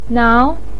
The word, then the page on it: nao: 2